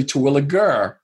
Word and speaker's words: Terwilliger: And it could be Jer or Er. It's actually Terwilliger.